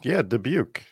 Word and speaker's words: Dubuque: Dubuque? – Dubuque.